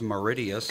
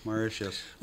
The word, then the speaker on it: Mauritius: I didn't know it was pronounced Mauritius. – Good for them. – And then what's number Mauritius. – One? – Mauritius. – That's how they say it, Mauritius? – Mauritius.